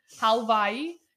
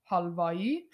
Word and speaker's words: Halvai: Now, there's a few ways to say it. It's a tough one. Okay. It's a tough one. But if you want to say it like you're a true Iranian, Halvai. If you want to say it like you're an American, think Hawaii. Halvai.